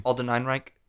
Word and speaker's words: Ehrenreich: Alden Ehrenreich.